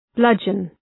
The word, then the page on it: bludgeon: ‘blʌdʒən